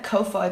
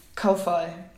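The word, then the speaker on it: kōwhai: I hope that I say this correctly, but in Māori, W and an H is pronounced an F, I think. So it's Ko-Fi.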